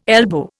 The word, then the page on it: elbow: Indian